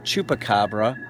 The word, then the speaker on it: chupacabra: That's how we pronounce it here, chupacabra. Or as they say in Puerto Rico, chupacabra.